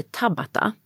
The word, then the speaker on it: Tabata: Och sen finns det en version av HIIT som heter Tabata. – Ja, den har jag hört talas om. – Ja, som man ska uttala på japanska.